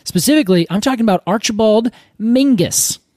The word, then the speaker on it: Menzies: Well, he says that Menzies is pronounced Mingus.